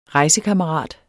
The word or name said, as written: REJSEKAMMERAT